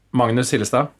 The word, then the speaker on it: Magnus Hillestad: I'm Magnus Hillestad.